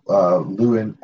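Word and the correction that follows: Lews Therin: I'm never going to pronounce the name right. – Luce Theron.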